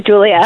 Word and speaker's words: Julijah: Yes, it is Julia. – Julia. Oh, okay. It's spelled J-U-L-I-J-A-H.